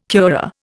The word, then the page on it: curare: Indian